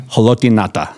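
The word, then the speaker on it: Haloti Ngata: Can you pronounce that last name one more time? – Haloti Nata. – There you go. – It's two words.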